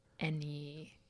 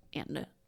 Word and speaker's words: Anne: Any. – Instead of like any, I'll just be like Anne.